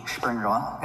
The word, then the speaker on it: Springerle: S-P-R-I-N-G-E-R-L-E. Springerla.